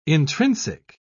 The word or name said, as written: intrinsic